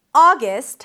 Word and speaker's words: August: August has two syllables. The first one is ah, as in father, ah. You can also round your lips to something more rounded, like the daughter vowel, ah, ah, and then gist, a g sound, a schwa, an s and a t, gist, gist.